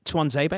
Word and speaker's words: Tuanzebe: Paul Pogba, Marcus Rashford, Jesse Lingard, Anthony Martial, and Axel Tuan, is it Tuanzebe? I think is how it's pronounced. – Yes, that's correct.